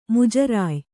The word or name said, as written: ಮುಜರಾಯಿ